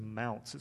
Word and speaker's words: Mounce: His last name is Mounce. It's spelled M-O-U-N-C-E, like the word ounce, with an M at the front.